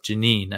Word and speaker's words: Jenin: It's Jenin. I always pronounce it wrong.